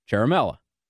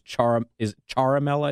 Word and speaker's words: Ciaramella: the name is Eric Ciaramella. Ciaramella, however you pronounce it. Somebody said it was Ciaramella, I think they said. The lady that called me to pronounce it Italian style, since I had it wrong.